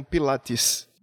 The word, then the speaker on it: Pilates: Não sei, todas as publicações que aparecem o nome dele é falado foneticamente como Pilates. Mas, de qualquer forma, a sobrinha dele disse que lembra de o nome ser pronunciado Pilotes.